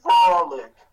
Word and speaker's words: Frolik: Frolik. Frolik, as in like frolic in the woods.